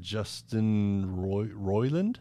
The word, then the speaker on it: Justin Roiland: Apparently, though, the charges that Justin Roiland, is that how you say it? – Yes.